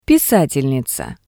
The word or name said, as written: pisatelniza